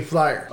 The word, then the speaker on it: Flyier: Mr. Hangtime, Jay Flyier.